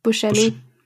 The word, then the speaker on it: Buscemi: Wie spricht man es richtig aus? – Buscemi. – Buscemi, genau.